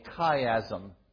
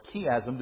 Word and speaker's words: chiasm: This is what is referred to in literature as a chiasm, or chiasm, depending where you're from and how you pronounce it.